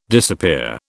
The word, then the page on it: disappear: British English